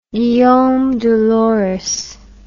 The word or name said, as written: Guillaume de Lorris